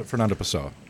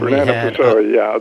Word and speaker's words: Pessoa: We had Douchebag, and I make sure I'm saying this right, Pessoa. – Yes, Fernando Pessoa. – And Pessoa,